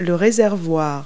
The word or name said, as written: fuel tank